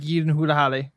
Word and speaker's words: Gyllenhaal: It's Gyllenhaal.